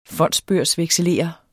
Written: fondsbørsvekselerer

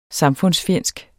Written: samfundsfjendsk

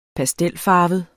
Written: pastelfarvet

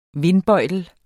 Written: vindbøjtel

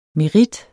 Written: MERITTEN

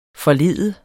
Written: forlede

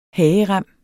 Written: hagerem

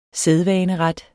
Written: sædvaneret

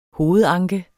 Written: hovedanke

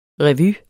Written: REVYERNES

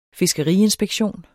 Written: fiskeriinspektion

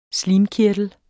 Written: slimkirtel

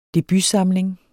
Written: debutsamling

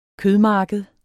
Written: kødmarked

